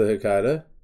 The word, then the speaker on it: Hecata: Now, the one group that I was kind of peeking at was the Hecata. I think that's how you pronounce it. – Okay, yeah, that is how you pronounce the Hecata.